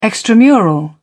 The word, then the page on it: extramural: British English